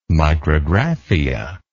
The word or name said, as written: micrographia